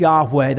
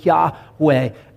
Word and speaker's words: Yahweh: YHWH, there were no vowels in the old Hebrew. We assume it's pronounced Yahweh. The pronunciation has been long lost, but it's likely Yahweh.